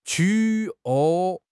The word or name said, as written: qu3